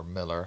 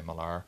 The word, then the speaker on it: Millar: Our previous episode managed to get a like from Mark Millar, or Miller, however you want to pronounce it. I say Millar.